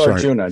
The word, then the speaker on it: Arjuna: Well, it's A-J-U-N-A, right? – Yeah. It's Arjuna, just like the classic Indian myth.